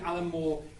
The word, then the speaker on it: Alan Moore: Yes, poor Pop Will Eat Itself wrote a song about including Alan Moore in a song, and they only did it because Alan Moore rhymes with loads of things, and you certainly won't find that in this song.